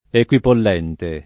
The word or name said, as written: equipollente